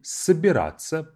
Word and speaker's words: собираться: Собираться, but of course, it's spelled as собираться. We say it собираться, which means to get ready or to pack and to get ready to go.